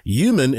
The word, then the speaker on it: Yuman: Human is spelled Y-U-M-A-N, human-speaking tribes.